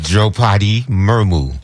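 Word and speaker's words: Droupadi Murmu: Drupadi Murmu. – There we go. – Okay. – And that is correct. That is very correct. Thank you very much. Congratulations, Drupadi Murmu.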